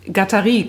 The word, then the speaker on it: Guattari: Die Amerikaner sprechen ihn Guattari aus. Aber er ist Franzose. – Und Guattari, nochmal, sag's nochmal? – Guattari, glaube ich.